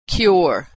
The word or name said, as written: cure